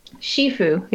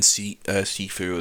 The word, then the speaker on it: Shifu: Shifu, which is how you're actually supposed to pronounce it. – I've heard it both ways, actually. I've heard Shifu and then Sifu as well.